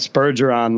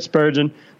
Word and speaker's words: Spurgeon: Our avid listener, Daniel Negreanu, was giving me a little gruff on Twitter for mispronouncing Jared Spurgeon's last name, having said Spurgeon last week. So it's Jared Spurgeon,